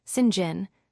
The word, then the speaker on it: St. John: For example, the display text might remain St. John, while the audio text uses a spelling that prompts Sinjin for a character whose name is pronounced that way.